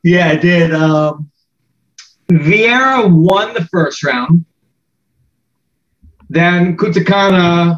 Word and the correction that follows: Kunitskaya: am I saying that right? – Kunitskaya kept it on the feet and kind of won there.